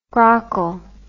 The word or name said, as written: grockle